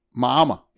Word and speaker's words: Miami: He pronounced it the way it was pronounced by Florida people, Miami. – Mama.